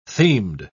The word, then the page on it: themed: British English